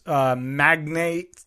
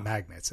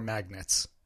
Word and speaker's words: magnate: Is it magnates or magnets? – How do you pronounce it? – Yeah. Do you pronounce it like magnate? – Mag-nate, like the name Nate. Mm-hmm. Magnet, like internet.